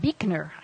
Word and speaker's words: Buechner: His name is pronounced Buechner. I never would have guessed that.